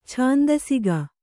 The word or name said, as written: ಛಂದಸಿಗ